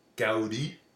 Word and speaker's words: Gaudí: Gaudí.